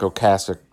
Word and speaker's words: Ocasek: And I know he's pronounced Ric Ocasek. I've heard it pronounced Ric Ocasek and Ric Ocasek either way.